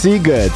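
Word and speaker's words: Sziget: Bo to jest bardzo ważna rzecz, bo pisze się Shiget, a wymawia się Siget.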